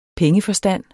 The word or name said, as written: pengeforstand